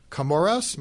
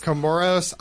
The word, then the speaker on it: Comoros: Her family is from the Comoros, am I saying that right? – Yes. – Comoros Islands and Guinea.